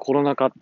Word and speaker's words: コロナ禍: ラジオとかでコロナ禍って言ってた時に、ああ、合ってた。